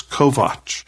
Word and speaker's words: Kovacs: "Your name is Kovac, K-O-V-A-C-S. He said, "That's the correct pronunciation.